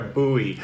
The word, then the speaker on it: buoy: That's how we pronounce it in America, buoy. But boy is how it's pronounced in other parts of the world.